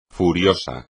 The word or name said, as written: furioso